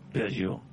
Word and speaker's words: Peugeot: Look at me trying to pronounce Peugeot the French way there. – Peugeot.